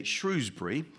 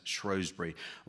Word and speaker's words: Shrewsbury: Opinions about how to pronounce the town's name differ in the north of the county. They tend to say Shrewsbury. To the south, it's known as Shrewsbury. Whilst in Wolverhampton, they say that poncy place full of toffee-nosed tossers.